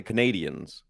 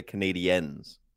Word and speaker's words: Canadiens: Yes, they are not the Canadians. They are the Canadiens. – Yeah, because it's spelled different.